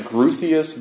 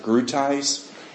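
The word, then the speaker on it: Groothuis: Douglas, it looks like Gruthius, but pronounce it Grutice.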